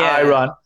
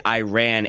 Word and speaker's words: Iran: in college, and everyone pronounced it Iran in college, like all the students, but our po- our political science professors, he always corrected us and said it is Iran.